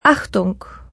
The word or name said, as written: Attention